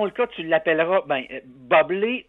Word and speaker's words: Bublé: She's right, Michael Bublé. It's pronounced Bublé. I know, thanks.